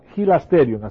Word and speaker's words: hilasterion: Se pronuncia hilasterion, así.